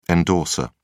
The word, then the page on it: endorser: British English